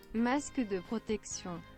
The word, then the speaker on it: masque de protection: Wie spricht man das aus? Okay, Moment. Aussprache Französisch. Moment. Aussprache French. Aussprache French. WTF. Mehr Wörter. Ach du. Aussprache Französisch. Mehr Kombinationen von Nicht-Französisch gegen die Aussprache. German into French. Nee, ich will einfach hier auf hier. Ja, französische Aussprache. Nein. Ja. – Masque de protection.